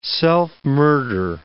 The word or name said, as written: self-murder